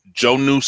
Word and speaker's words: Jonnu: I think it's pronounced Jonnu Smith, the tight end out of Tennessee. Is that how you say it? – I think so.